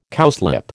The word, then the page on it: cowslip: American English